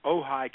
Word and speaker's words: Ojai: And I compliment you on being able to pronounce all those things correctly, like Ojai, California. You wouldn't believe how many interviewers have messed that one up.